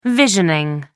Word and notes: visioning: American English

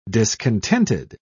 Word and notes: discontented: British English

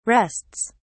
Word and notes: rests: British English

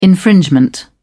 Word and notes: infringement: British English

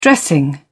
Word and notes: dressing: British English

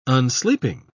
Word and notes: unsleeping: British English

